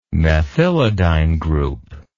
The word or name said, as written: methylidyne group